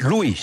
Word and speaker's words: Lewis: Se escribe Lewis. Luis.